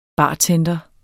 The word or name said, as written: bartender